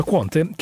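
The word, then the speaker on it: Qwant: Allora, dal 2013 è attivo un motore di ricerca che si chiama Quant, è scritto con la Q di quadro WANT, Quant, ho detto anche Quant, però si pronuncia Quant, che ha ricevuto decine di milioni di euro di finanziamenti anche dalla Cassa Depositi e Prestiti francese.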